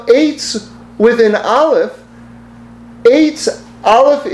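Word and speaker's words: Eitz (עץ): We have to spell Eitz, get to the place where we spell Eitz, Aleph Tzadi. It would still be pronounced Eitz, but we're turning the Ayin into an Aleph.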